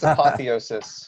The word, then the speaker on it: apotheosis: When I told you about this, you sent me this picture of the apotheosis? – I think it's apotheosis. – The apotheosis of Washington. – I believe is the pronunciation.